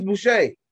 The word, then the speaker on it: Boucher: Ain't that his name, Chris Bosher? Or Boucher, whatever, ain't something like that. – Oh, Chris Boucher. – Chris Boucher. – I was gonna say Chris Bosher. – It's spelled like that.